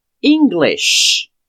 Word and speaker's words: English: Quando eu soletro, fica E-N-G-L-I-S-H. A primeira letra é um E, que nós pronunciamos I. English. É importante no final você fazer esse sh English. Às vezes, quando a pessoa está aprendendo, é comum que ela fale English. Mas não, English. Como se você estivesse pedindo para a pessoa ficar quieta.